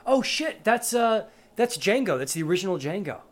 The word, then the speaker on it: Django: "My name's Django. The D is silent."